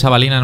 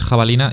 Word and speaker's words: Havalina: Y es Avalina, no es Jabalina, y es H muda, por Dios.